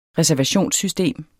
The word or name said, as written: reservationssystem